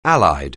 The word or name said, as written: Allied